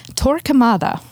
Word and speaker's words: Torquemada: Torquemada.